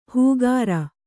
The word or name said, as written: ಹೂಗಾರ